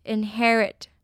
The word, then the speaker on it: inherit: inherit inherit 那发音不太一样哦 Inherit 呢, 它指的是继承的意思它的H-E-R的E呢是发E的音 Inherit 我们最后再来看一次今天的例句 These young entrepreneurs are having a tremendous impact in their cities.